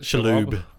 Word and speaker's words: Shalhoub: So Tony Shalhoub is that how you pronounce it? – Shalhoub. – Shalhoub. Sorry.